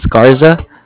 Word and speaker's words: Garza: A-L-F-O-N-S-O. And then last name is Garza. G-A-R-Z-A.